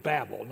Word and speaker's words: Babel: So be sure and teach these kids Babel, not Babel, so they'll know you're from Texas.